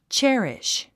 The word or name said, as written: cherish